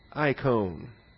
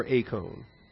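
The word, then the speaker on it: acone (εἰκών): I guess I'm teaching our beginning Greek students to pronounce the epsilon iota diphthong as an A. Unless it's at the beginning of a word where sometimes it's pronounced I. Acone.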